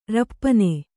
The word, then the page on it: ರಪ್ಪನೆ: kannada